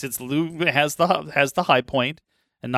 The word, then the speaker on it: Lughnasadh: Lughnasadh.